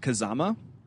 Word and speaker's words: Kazama: I believe his last name is pronounced Kazama.